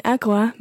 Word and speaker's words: agua: Un agua mineral. – Now, un agua mineral. Listen to the way I say agua. Agua. – Agua. – Agua. – Agua. – Okay, it's a very, very soft G. Agua.